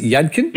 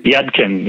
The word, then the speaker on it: Yudkin: by John, how do you pronounce it? Yudkin. Yudkin. yes. Yudkin.